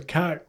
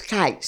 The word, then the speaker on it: Kight: I know of, there's a Kite Road. I'm sure that's of the same lineage. – How you spell that? – K-I-G-H-T. That little cemetery in Manor. – Right. – It's Kite Cemetery.